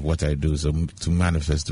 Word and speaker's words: Manifest: So it's M.A.N.I.F.E.S.T., but I still pronounce manifest.